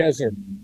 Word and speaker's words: Tel Gezer: Well, now, my understanding is that you co-led a 10-year excavation, I think I probably read this in Christianity Today, at Tel Gezer, if I'm pronouncing it. Tel Gezer, yes.